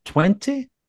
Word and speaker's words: Twente: Twente? – Yeah.